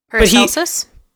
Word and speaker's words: Paracelsus: Paracelsus? – Thank you.